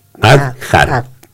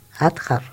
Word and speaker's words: Hadjar: Se escribe H-A-D-J-A-R. Adjar. – Adjar, sí.